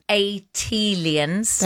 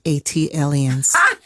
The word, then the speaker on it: Atlian: Tayari, just as Jill did for Philly, as an unofficial ambassador for Atlanta, an Atlians. – That is pronounced Atlians. – Thank you. – Like Atlanta alien, Atlian.